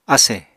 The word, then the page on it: asè: Apatani